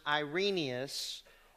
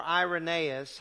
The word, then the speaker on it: Irenaeus: We don't have the original writings of Cerinthus, but he is addressed by one of the church fathers, Irenaeus, or Irenaeus, however you choose to pronounce that. It's pronounced both ways.